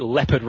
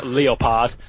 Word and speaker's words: Leopard: I'd love to call it Leopard Racing, but I am reliably informed that the sponsor's name is pronounced Leopard.